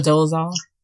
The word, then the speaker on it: Dolezal: So it, of course, brought to mind the case of Rachel Dolezal. Am I saying that right? – That's how I say it. Yeah, okay. Rachel Dolezal.